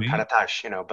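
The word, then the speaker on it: Karataş: It's a Turkish name, and the S is actually pronounced SH. So that's even, it's really Karatash or Karatash, but, if someone says Karatas, that's close enough.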